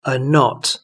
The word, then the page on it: a knot: British English